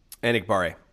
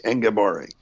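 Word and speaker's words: Enagbare: Enigbare. – How do you say it? – J.J. Enigbare. Kingsley, but I think he goes by J.J. – All right. Enigbare.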